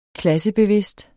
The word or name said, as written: klassebevidst